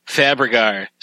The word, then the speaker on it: Fabergé: It's all right. It's Fabergé. Just the way it's spelled. Kind of easier than people think. Go for it. Take two. – All right. Dale Fabergé.